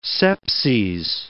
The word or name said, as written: sepses